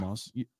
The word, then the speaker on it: Lamaze: Lamaze. Is that right? – It's yeah. This is more of like. – Did I say that right? – Yeah. – Lamaze.